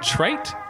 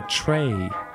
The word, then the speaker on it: trait: Is it trait or tray? – Trait. – It's trait, right? I've heard people say tray.